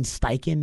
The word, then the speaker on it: Steichen: and we saw Shane Steichen is how you say his name, I believe, what he did with that Jalen Hurts experiment, turning him into an MVP-caliber quarterback.